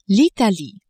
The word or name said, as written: Italie